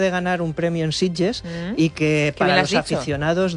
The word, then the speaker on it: Sitges: No ha dicho Sitges, ha dicho Sitges. – Lo ha dicho muy bien. – Lo ha dicho muy buena pronunciación catalana.